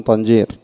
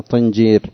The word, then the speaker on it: طنجير: قال قوله طنجير بما يهمون فيه قولهم طنجير فتح الطاء، وهي على قياس كلام العرب بالكسر طنجير، إذ لم تنطق في هذا المثال إلا بفعل بكسر الفاء مثل صنديد.